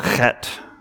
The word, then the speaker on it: chet: This is, if you want a little pronunciation fun, this is pronounced chet. You